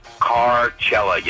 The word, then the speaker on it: Coachella: But I got to join the pronunciation police department and say, you idiots, keep mispronouncing Coachella. It's Carcella, you dumbasses.